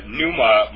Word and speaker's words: pneuma: And then when it talks about the Spirit, or the pneuma, by the way, the word pneuma might be more correctly pronounced pneuma in the Greek. The Greek form might be correctly pronounced pneuma rather than pneuma, by the way.